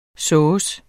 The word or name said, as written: sovse